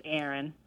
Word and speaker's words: Erin: Oh, my name's Aaron. – Aaron. – Spell that. – E-R-I-N.